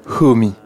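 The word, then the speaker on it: Homi (хөөмий): Le mot mongol qui désigne cet art se transcrit en alphabet latin K-H-O-T-R-E-M-A-O-T-R-E-M-I-I. Mais ça se prononce comment, au juste ? – Homi. – Alors, homi, ça c'est une des manières de translittérer, hein, évidemment, selon la norme adoptée. Homi ou homei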